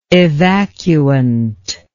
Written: evacuant

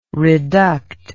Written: reduct

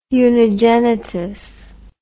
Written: unigenitus

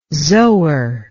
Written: zoar

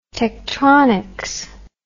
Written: Tektronix

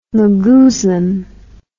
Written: magoozlum

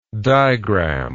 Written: digram

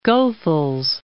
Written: Goethals

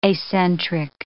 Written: acentric

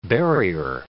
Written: burier

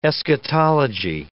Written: eschatology

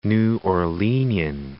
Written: New Orleanian

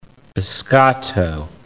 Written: biscotto

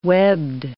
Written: webbed

